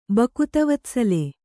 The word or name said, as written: ಭಕ್ತವತ್ಸಳ